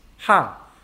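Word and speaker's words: ha (ห้า): In Thai language, the number 5 is pronounced ha.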